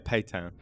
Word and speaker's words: Pétain: So, did you say his name is pronounced Pétain? Pétain? – Pétain.